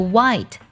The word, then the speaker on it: white: 第四组呢，就是 W H 开头，H 不发音，像 Why 为什么，还有 White 白色，其中的 H 都不发音。